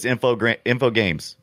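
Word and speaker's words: Infogrames: An Infograms game. – Infograms. – Yeah. Infograms. That's right. – Maybe that's just how Americans say it. I'm always saying you got it wrong. It's Infogames.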